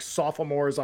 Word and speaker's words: sophomore: How many syllables did you just add to the word sophomores, by the way? – Like sophomore? – At least yeah. One. Like you gave us like the King's English pronunciation of sophomore yeah. just now. Usually yes. everyone cuts sophomore. The O off. Because it is spelled sophomore, yeah. but everyone just says sophomore.